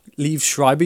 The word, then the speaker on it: Liev Schreiber: And his name is, I don't know how to pronounce it, Liev Schreiber? – Liev Schreiber, yeah.